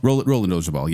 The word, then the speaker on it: Roland Orzabal: The one guy, Ronald Orzabal, I can never say his name. Roland Orzabal, yes.